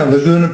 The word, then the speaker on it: Laguna: Yeah, Laguna Productions. It's like Laguna Beach.